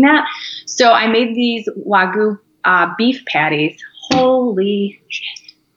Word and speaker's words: Wagyu: So as far as diet goes, I just discovered, okay, I knew of Wagyu, I don't know if I'm saying it correctly, steak.